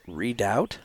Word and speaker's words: Redoubt: Re- how do you how do you say that? Redoubt. Redoubt.